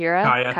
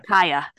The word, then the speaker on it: Kaya: Kyra, Kaya, Kaya. – Kaya.